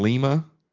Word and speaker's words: Lima: You ever spent time in Lima? – Okay, first off, it's pronounced Lima in Ohio.